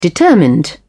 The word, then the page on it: determined: British English